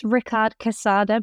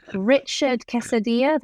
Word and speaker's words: Ricard Quesada: Yeah, so it's Ricard Quesada, but nobody pronounces it correctly. I get all the versions. I get Richard Quesadilla, that's a good one.